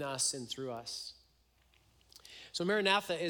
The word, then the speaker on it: Maranatha: So if you were to pronounce the word Maranatha, and if you were to pronounce it this way, Maranatha, so the emphasis on the Marana and then Tha, it would mean our Lord come.